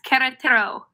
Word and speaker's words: Querétaro: Do you know how to pronounce where Sofia lives? – Yes, Querétaro.